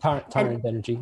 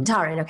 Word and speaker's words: Tarun Banerjee: Kevin Young, to run... Banerjee, I believe. Is that how you say it? – Taran Banerjee. – Mm-hmm. Taran, okay.